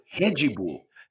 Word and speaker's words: Red Bull: He goes, the D's are pronounced like J's. The R's are pronounced like H's. So Red Bull is Hedgy Bull.